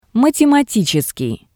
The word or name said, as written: Mathematicseskii